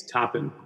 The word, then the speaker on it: Toppin: It's Toppin, but he might be.